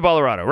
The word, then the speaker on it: Ballarato: David Ballarato. Rhymes with Colorado.